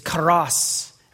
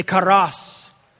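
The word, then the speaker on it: kairos: And the word time in these verses is karos. Everybody say karos. You got to roll your R there.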